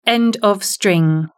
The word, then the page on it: end of string: British English